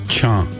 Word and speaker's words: Cha: Cha. – Cha. – Yes, cha means rain.